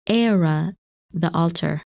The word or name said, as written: Ara